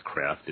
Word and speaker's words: Kreeft: I think it's pronounced Kreft. It's spelled Kreeft, K-R-E-E-F-T.